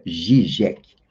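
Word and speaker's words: Žižek: escreve-se Zizek e se pronuncia Zizek,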